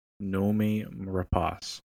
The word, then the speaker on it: Rapace: Her name is pronounced Nomi Rapace. – So it's like the salsa, but with like a ra. Rapace.